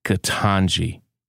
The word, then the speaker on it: Ketanji: I just want to teach you how to say the name of Ketanji Brown Jackson. Okay, let's put Brown and Jackson on the shelf. We know how to say Brown and Jackson. Her name is Ketanji.